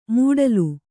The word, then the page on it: ಮೂಡಲು: mūḍalu